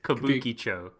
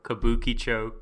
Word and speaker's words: Kabukicho: The K word. – Kabuchiko. Oh, Kabuki. Yeah, eh. Krispy Kreme. Krispy Kreme. – I do it all the time too. Kabukicho. Kabukicho. Kabukicho.